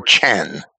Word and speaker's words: chen: The usual Hebrew words for grace and kindness, which are ken. I should probably pronounce that with a hard CH, ken or chen, and chesed or chesed, are quite close in spelling, and the respective roots are very similar in meaning.